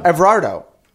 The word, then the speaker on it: Everardo: Everardo. Everardo. – Yeah. – Is it so I have to put the ER out there because that's your name.